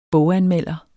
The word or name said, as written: BOGANMELDEREN